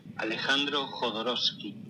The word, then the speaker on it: Alejandro Jodorowsky: Alejandro Jodorowsky.